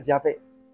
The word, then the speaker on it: Yahvé: Ustedes recuerden que va a variar la forma de pronunciarlo. La forma más correcta sería Yahvé. Jehová no, aunque prevaleció más en las Biblias.